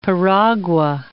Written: piragua

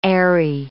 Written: aerie

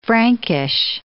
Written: Frankish

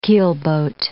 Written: keelboat

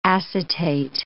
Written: acetate

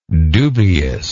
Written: dubiousness

